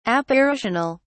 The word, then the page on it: apparitional: British English